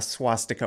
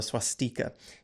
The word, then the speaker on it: swastika: Because in English, it's called a swastika or a swastika. You can pronounce it different ways, swastika or swastika.